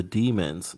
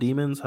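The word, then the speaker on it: Deamins: Let's talk about your group, The Demons. Now, oh, sure. – For those who want to look it up, it's spelled a little differently. It's D-E-A-M-I-N-S.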